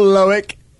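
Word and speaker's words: Loïc: Hello, Loic.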